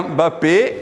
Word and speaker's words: Mbappé: non lo pronuncio bene, ma si chiama Mbappé. – Mbappé, Mbappé.